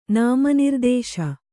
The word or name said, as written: ನಾಮನಿರ್ದೇಶ